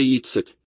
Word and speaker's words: Yitzhak: Yitzhak significa riso e vocês pronunciam como Isaac, mas na verdade é Yitzhak.